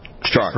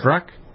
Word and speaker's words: Strzok: How do you pronounce his last name? – Strzok. – Strzok? Strzok. S-T-R-Z-O-K.